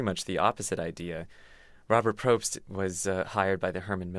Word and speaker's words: Probst: And talk about the guy, his name is Robert Probst, I hope I'm pronouncing that correctly. – That's right, yeah.